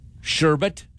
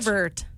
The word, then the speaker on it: sherbet: Sherbet? Sherbert? – Sherbert. – Yep. There's no second R in there, though.